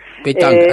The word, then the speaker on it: pétanque: Pétanque, así este se pronuncia, juego, bien.